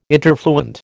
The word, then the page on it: interfluent: American English